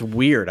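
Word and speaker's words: Wyrd: It's actually pronounced Weird.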